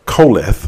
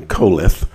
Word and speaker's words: Qoheleth: Uh, it's spelled, it, it transliterated in English as Q O H E L E T H, but it's pronounced in the best southern uh, Hebrew I can do, uh, Koleth.